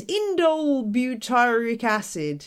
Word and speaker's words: indole butyric acid: indole butyric acid, or IBA.